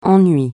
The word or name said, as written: ennui